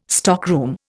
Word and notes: stockroom: Indian

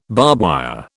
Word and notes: barbwire: British English

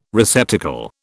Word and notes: receptacle: British English

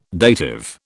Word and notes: dative: British English

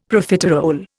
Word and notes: Profiterole: Indian

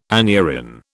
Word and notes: aneurin: British English

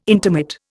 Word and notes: intermit: Indian

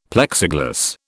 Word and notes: Plexiglas: British English

